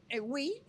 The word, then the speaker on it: oui: Se escribe O, U, I, Bistro. – Wii es sí en francés.